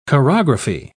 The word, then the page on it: chorography: British English